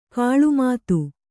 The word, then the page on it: ಗಿಳಿಮಾತು: giḷi mātu